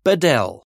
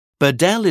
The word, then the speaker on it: Badel: My name is James, and my family name is Bedell. Bedell is spelt B-A-D-E-L.